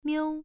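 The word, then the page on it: miu: tone 1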